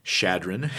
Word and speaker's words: Chadron: So for everyone, that's chadron. – Chadron. – Chadron. How many times have you been up to the top of Sea Hill here in Chadron? – Oh, boy.